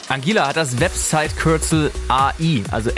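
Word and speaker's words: Anguilla: Angela! Habe ich richtig gesagt? – Ja, würde ich sagen, weil es tatsächlich zu Großbritannien mit dazu gehört.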